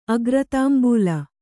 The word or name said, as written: ಅಗ್ರತಾಂಬೂಲ